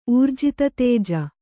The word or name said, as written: ಊರ್ಜಿತತೇಜ